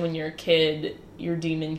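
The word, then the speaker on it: daemon: Yeah, so my favorite part of this universe is that everyone has a demon, which is spelled with, like, the A and the E connected to each other. – The Aeneid A-E. – So it's like, yeah, D-A-E-M-O-N-S, but the A and the E are connected. Apparently it's pronounced demon.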